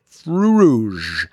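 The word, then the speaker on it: Fruits Rouges: Fruits Rouge is the French way of saying that, but it's still spelled the same as Fruits. Fru Rouge.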